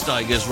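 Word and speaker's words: Stigers: Curtis Steiger's then. As I think, is it Dez who's put it? Curtis Steiger's rhymes with tigers.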